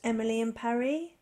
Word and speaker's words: Emily in Paris: and a sharp-eared listener wrote in to say that actually it's pronounced Emily in Paris, and that has actually been confirmed by Netflix. That's how it should be pronounced.